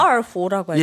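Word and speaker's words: 얼후 (二胡): 얼후라고 하잖아요. – 예, 이호. 네. 한자로 이호. 중국어 발음으로 얼후라고 하죠.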